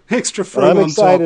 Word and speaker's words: Frome: Did you say Foom? – Froome, F-R-O-M-E. They pronounce it Froome.